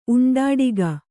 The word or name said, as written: ಉಂಡಾಡಿಗ